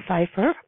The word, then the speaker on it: Pipher: So I am so delighted to have Mary Pfeiffer, Pipher here. See, I almost said it wrong again, but Mary told me that it's like Pfeiffer,